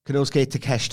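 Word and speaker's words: Konosuke Takeshita: Kanosuke Takeshita. – Thank you. I'm going to get his name right eventually. – You're already getting it right, sir.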